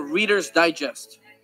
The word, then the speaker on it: Reader's Digest: Yo no sé cómo pronunciar. Readers. – Oh, Reader's Digest.